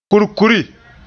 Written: kurkuri

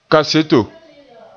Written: kaseto

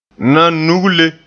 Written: nanugle

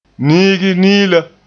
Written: niigi-niila

